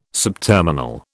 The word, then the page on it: subterminal: British English